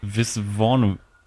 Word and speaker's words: Viswanathan: Die wird von Geraldine, Entschuldigung, wenn ich es jetzt falsch ausspreche, Visnathan Also sie ist ja Australierin, ne? Deswegen hätte ich jetzt gesagt, Viswanathan.